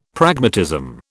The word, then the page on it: Pragmatism: British English